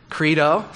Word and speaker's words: credo: Credo. Some of you, wow. I should have said credo. I pronounced that wrong last week.